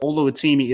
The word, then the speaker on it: Oluwatimi: Just going to call him Olu because I think I just butchered his first name terribly, but Oluwatimi is how you pronounce the last name. So at least I got the last name right.